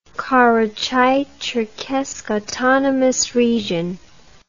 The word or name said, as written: Karachai-Cherkess Autonomous Region